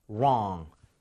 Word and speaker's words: wrong: 우리가 wrong 할 때는 r 발음이기 때문에 입 모양을 좀 모으고 혀를 많이 당겨 주세요. 제가 두번 할게요. wrong.